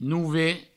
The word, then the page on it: Nove: Nouve